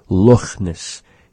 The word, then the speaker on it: Loch Ness: Have you ever heard a Scotsman pronounce Loch Ness? Loch Ness. He pronounces the CH in the word just like we do in Nuchalk, the cha sound. It's sort of a raspy sound, resembling slightly an H sound.